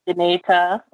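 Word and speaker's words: Dinétah: The Dineta, did I say that right? – Dineta. Mm-hmm.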